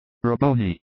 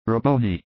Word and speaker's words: Rabboni: I am writing, Rabboni, because I see that it is spelt so in the gospel. But every time I hear Mary call him, she seems to be saying, Rabboni. With an M, and not with an N.